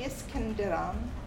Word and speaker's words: Iskenderun: Dilshat was born in Iskenderun.